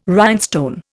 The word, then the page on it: rhinestone: Indian